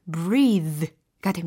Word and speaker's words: breathe: 그러니까 발음이 breath가 아니고 breathe가 됩니다. breathe. 하실 수 있겠죠. 네, breathe. 요거 발음할 때 머리가 z z 진동이 느껴지셔야 돼요.